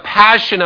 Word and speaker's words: passio: Sometimes referred to as the Passion of the Christ. That word passion comes from the Latin word, I believe it's pronounced passio, P-A-S-S-I-O, I'm not a Latin major, which is the Latin word for suffering.